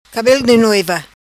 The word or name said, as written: Cabelo di noiva